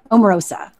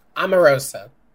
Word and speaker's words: Omarosa: Omarosa. – Omarosa. – Omarosa.